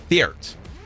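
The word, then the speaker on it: Theart: ZP, just the two initials, and Thiert.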